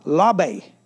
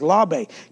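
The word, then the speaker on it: labe (לב): And you pronounce it like labe. It's spelled L-E-B, but it's labe.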